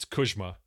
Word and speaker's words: Kuzma: It's actually, if you want to go old world pronunciation, I've mentioned this before. It's Kuzma. It's more like a J for Polish or like something like that.